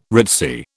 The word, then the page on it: ritzy: British English